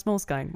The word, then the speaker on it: Moleskine: I thought you were going to say, Moleskine users have asked us how to pronounce Moleskine. Well, it's Moleskine.